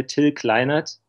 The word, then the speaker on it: Till Kleinert: In German, we would say Till Kleinert. – Okay. – So it's very flat. It's the German way to say it.